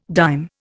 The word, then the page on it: dime: Indian